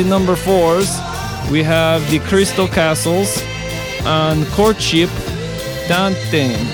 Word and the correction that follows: Courtship Dating: Oh no, I mispronounced. Courtship Dating.